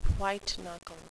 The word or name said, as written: white-knuckle